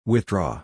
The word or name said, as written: withdraw